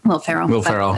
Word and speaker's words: Will Ferrell: If you ever wanted to check out the Eurovision movie with Will Ferrell on Netflix. Did I say it wrong again? – Will Ferrell.